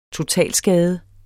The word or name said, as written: totalskade